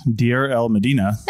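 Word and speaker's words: Deir el-Medina: The only other large cache of ostraca has been found in the ancient village of, I don't know how to say this, Deir el Medina. – Yeah, that's pretty good.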